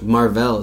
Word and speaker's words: Mar-Vell: It's going to be pretty much just Captain Borvo, maybe? Is that how you pronounce it? – No, Mar-Vell is two words.